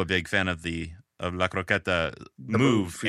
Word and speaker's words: La Croqueta: Yeah, La Croqueta. Very good. Very good pronunciation.